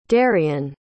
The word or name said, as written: darien